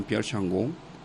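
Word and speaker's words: Changeux: intereses filosóficos que se llama Jean-Pierre Jean Changon, que se escribe Changeaux, que se llama El hombre de verdad, que también sería una buena introducción a al asunto.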